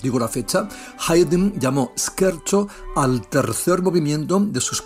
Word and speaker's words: scherzo: Pues se escribe scherzo, scherzo, pero no se pronuncia scherzo, jóvenes, que se recuerdan al anfibio correspondiente, sino scherzo, scherzo, en italiano, y significa broma, broma, bambino.